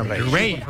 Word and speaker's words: Raye: Ray. Ray. Se escribe Raye.